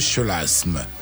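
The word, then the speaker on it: chleuasme: Et euh, cette façon de faire s'appelle le chelasme.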